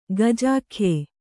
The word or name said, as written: ಗಜಾಖ್ಯೆ